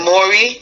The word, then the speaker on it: polyamory: Polymory. – Polymory? I have a feeling you're not saying it right. I don't know how to Polyamory. – Say it. – Polyamory. It's P-O-L-Y-A-M-O-R-Y. – You can't even pronounce the letters right. – P-O-L-Y-A-M-O-R-Y.